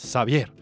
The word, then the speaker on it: Xavier: Emma Orbiler, Emmanuel Orbiler con su disco eh, Xavier, ¿ok? – Eh, es Javier con X, pero se pronuncia como en francés, Xavier.